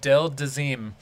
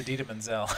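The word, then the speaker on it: Idina Menzel: Del Dazeem. It's Adita Menzel.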